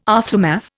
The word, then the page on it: aftermath: Indian